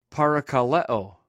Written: paraka

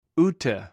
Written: oute

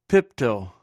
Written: piptw

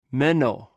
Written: menw

